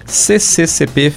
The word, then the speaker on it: CCCP: Fiquei com a sensação de falar de um C a mais, mas não, falei certo. CCCP, Fedele Alla Linea e a canção Io Sto Bene.